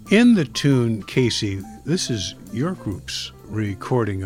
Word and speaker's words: Queysi: One of the tunes of Kerry Thomas that you play today, it's spelled Q-U-E-Y-S-I, but it's pronounced Casey.